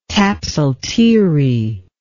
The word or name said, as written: tapsalteerie